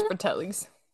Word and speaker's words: Fratellis: Not sure how to pronounce that. Fratellis. I think it's Fratellis.